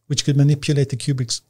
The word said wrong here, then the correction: Rubik's: the Cubic's cube. I forget. – Rubik's. I, Rubik's. – I can never pronounce it. – Rubik's cube.